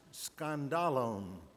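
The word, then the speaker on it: skandalon (σκάνδαλον): The word that is translated stumble, used so often in the New Testament, in the original language is pronounced skandalon. Skandalon.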